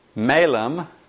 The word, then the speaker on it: malum: In Latin Christianity, malum, M long A, L-U-M, means evil. Malum. Uh-huh, malum, right? The word for apple in Latin with a short A is malum.